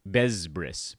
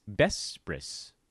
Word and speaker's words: Besbris: A lot of people pronounce it Besbris, but it's Besbris. Soft sounds.